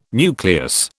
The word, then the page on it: nucleus: British English